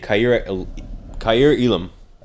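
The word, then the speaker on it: Kaiir Elam: Kansas City Chiefs back-to-back picks take Kyir Elam. I think that's how you pronounce his name, right? – Yes.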